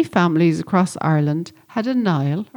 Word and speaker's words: Niall: Or maybe it's a variant such as O'Neill, N-E-A-L, or MacNeil, or Neil, or Nielsen. The ancient Irish given name Niall, sometimes pronounced Neil, comes from the Irish for warrior.